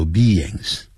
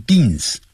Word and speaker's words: beings: Human 就是人，人的 H-U-M-A-N Human beings. Beings. 先念 B, 再来一个 ings. Beings. 啊，不要念 beans. 因为 B-E 念成 B, I-N-G 念成 N.